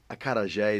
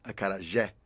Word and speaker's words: acarajé: Teach me how to say it first. – Acarajé. – Acarajé. – Acarajé. – Acarajé. – Yeah, that's it.